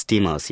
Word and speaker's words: Demos: By the way, am I pronouncing that correctly? – It's Demos, yes.